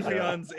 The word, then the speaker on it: Sufjan: Sufjan's in and out, yeah.